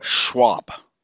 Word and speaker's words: Schwab: Well, I mean, I would the first thing I would do is I would tell Klaus Schwab, how do you pronounce his last name anyway? – Schwab.